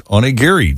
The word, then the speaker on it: onigiri: Kate says, yes, onigiri.